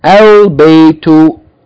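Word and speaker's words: al-baytu (البيت): The first word, anyone, the first line, the first word should be read as al-baytu, yani a moon letter.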